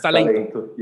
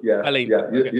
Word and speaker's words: talento: Or is it Talente? No, it's Talento. – Talento. You said it right.